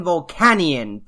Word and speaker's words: Volcanion: Magearna and Volcanion. Tell me I didn't screw that one out. – Nope, you're good.